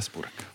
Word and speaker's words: Spurek: Sylwia Spurek.